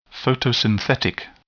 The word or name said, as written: photosynthetic